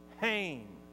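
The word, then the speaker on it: chen (חן): It's actually spelled, the English spelling would be H-E-N, and we might pronounce it hen, except the E is long, and the H, in order to pronounce it, you got to clear your throat, okay? So it's kind of like chen, all right?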